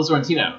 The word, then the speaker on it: Sorrentino: Pablo Sorrento is... – Yes, Pablo Sorrentino. – Yes, sorry, I said his name wrong.